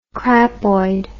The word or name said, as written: crapoid